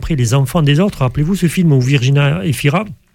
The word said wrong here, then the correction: Virginie Efira: Virginie Efira, oui. – Vous prononcez à l'italienne.